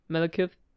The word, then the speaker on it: Malekith: Gespielt von Christopher Eccleston ist der Anta Antagonist Malekith oder Malekith, je nachdem, ob man das auf Deutsch oder Englisch aussprechen möchte.